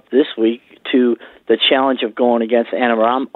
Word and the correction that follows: Anarumo: Anaramo or however you say his name. – Yeah, Anarumo. Uh, Anarumo, yeah. I mean, because that guy is e- a quality defensive coordinator and right up there with the best in the league.